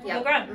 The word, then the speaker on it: Lagrone: Lagrone, mm-hmm. Just like it's spelled.